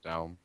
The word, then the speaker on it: Daum: Is it Daum or Dam? – Daum. – Daum. Daum. D-U-A-M. Daum. – That is D-A-U-M.